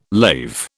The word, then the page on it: lave: British English